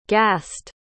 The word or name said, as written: ghast